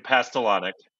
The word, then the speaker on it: Pastalaniec: Hey, guys, it's Braden Pastelonic.